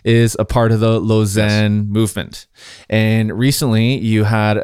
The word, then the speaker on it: Lausanne: It's Lausanne. – Oh, Lausanne.